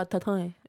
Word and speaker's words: tostones: So wait, also Puerto Ricans pronounce... all the letters of the word tostones. Yes? – I think so. I feel like, because my Cuban friends are like, oh, tatone. – I think we eat more of like the last syllable is the one we breathe more.